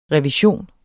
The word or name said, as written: REVISIONER